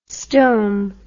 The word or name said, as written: stome